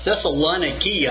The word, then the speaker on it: Thessaloniki: Thessaloniki, I think, is the way they would pronounce that today in modern Greek.